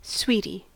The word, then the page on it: sweetie: American English